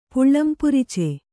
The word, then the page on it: ಪುಲ್ಲಂಪುರುಚಿ: pullam puruci